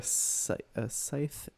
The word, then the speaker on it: scythe: Is there a scythe involved? Is that how you say it? – Yeah.